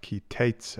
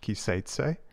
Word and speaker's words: Ki Teitse: I'm your host, and this week I'll be addressing Parashat Ki Teitse. You may also hear it pronounced Ki Seitse, which is Hebrew for when you will go out.